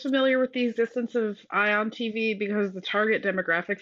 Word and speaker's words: Ion: It's Ion like the, you know, the charged atom.